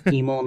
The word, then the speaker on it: Emil: Probably don't love crowds and only love some people, but my name is Emil Nguyen, spelled E-M-I-L, kind of like evil but with an M, and so I think that helps people remember how to say my name.